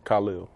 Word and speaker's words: Khalil: How do you pronounce your first name exactly now? – First name, you pronounce it Khalil.